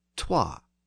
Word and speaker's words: twa: But I should note that the original form of the word still survives in Scots, where the number is still pronounced as twa.